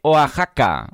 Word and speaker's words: Oaxaca: Landscape dice que se pronuncia exactamente Oaxaca.